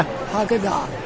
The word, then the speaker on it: Haggadah: How do you say Haggadah? – Haggadah. That's good. – Haggadah.